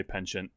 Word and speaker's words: penchant: Also, you do say penchant. You say the CH.